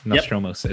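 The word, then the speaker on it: Nostromo: Nostromos is how you say it, by the way.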